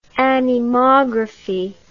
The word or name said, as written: anemography